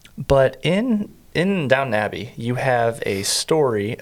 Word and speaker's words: Downton: Did you watch Downton Abbey at all? – I did not, but I appreciate your correct pronunciation. – Not downtown.